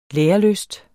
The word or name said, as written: lærelyst